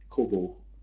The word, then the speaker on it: Kobo: K-O-B-O? – The Kobo. – K-O-B-O. – Yeah. – K-O-B-O. Is that how you pronounce it? – I don't know. – I'm the one. – The Irishman that knows English. I'm a Texan. Come on, give me a break. – I'm the one who traditionally has the role of butchering pronunciations. – Kobo. I would say Kobo is what I would say. – Was calling it. Yes. – Kobo. Okay, fair enough.